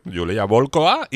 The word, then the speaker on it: Yulia Volkova: Que así es como se llama una, y Yulia Volkova, ¿vale? Lo he dicho bien, ¿no? Sí, Yulia Volkova y Lena Katina.